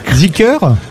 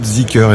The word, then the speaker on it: Zeekr: parce que Geely Auto c'est facile, mais Z2EKR qui est une marque qui va arriver aussi, vous la prononcez Zeekr. – Alors ça va être Zeekr, effectivement.